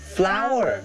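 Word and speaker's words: flour: จริงๆแล้วมี 2 คำที่ออกเสียงเหมือนกันแต่ความหมายก็ต่างกันเช่นที่แก้มนะครับอันนั้นจะเรียกว่า flower แต่ในหม้อนะครับหรือว่าแป้งอันนั้นเรียกว่า flower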